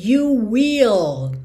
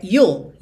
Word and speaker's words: you'll: Muitas vezes a pessoa pensa em dizer you will, como duas sílabas mesmo. You will, é you'll, you'll, é uma sílaba só, tá?